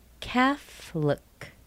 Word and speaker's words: Catholic: Catholic. Catholic. This might surprise a lot of people. This does not have three syllables. It's not Catholic. It's Catholic. That O is missing.